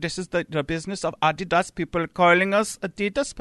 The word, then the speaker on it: Adidas: Adidas. – Which is the actual German pronunciation.